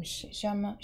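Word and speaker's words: Shammah: Shama. Song rhymes with Neshama.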